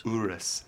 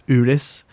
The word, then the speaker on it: Urus: Urus? – Urus. – Urus.